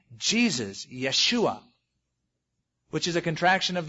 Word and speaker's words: Yeshua: Yeshua, we would call it Joshua. Joshua, it was a common Hebrew name, except the Hebrews don't pronounce the J, they say Yeshua.